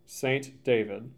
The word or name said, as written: St. David